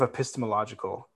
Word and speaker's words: epistemological: So I think there's a lot of ideas going on, but I think the main idea is sort of epistemological. I definitely mispronounced epistemological. Is that how you say it? – That was right.